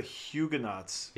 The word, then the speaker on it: Huguenots: The answer, and I really don't know how to say this, is the Huguenots.